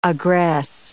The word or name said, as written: aggress